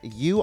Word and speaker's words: Yew: U online, Y-E-W, but you really just have to say it like, U.